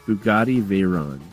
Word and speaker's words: Bugatti Veyron: Bugatti Veyron.